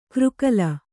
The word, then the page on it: ಕೃಕಲ: křkala